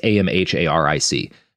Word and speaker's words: Amharic: I think Amharic is how it's pronounced. It's Amharic.